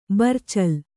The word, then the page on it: ಬರ್ಚಲ್: barcal; Kannada